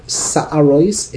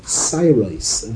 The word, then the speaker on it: sairois: So the sa'arois, it's sairois.